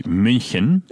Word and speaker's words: München: Munich is good. – Munich. – The Germans call it München.